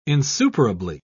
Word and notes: insuperably: British English

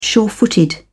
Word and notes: surefooted: British English

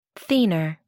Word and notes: thenar: American English

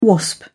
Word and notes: wasp: British English

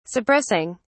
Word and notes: suppressing: British English

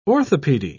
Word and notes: orthopaedy: British English

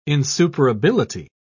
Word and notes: insuperability: British English

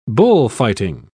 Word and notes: bullfighting: British English

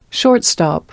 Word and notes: shortstop: British English

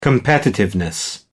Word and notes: competitiveness: British English

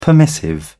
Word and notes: permissive: British English

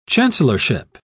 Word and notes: chancellorship: British English

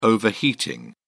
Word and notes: overheating: British English

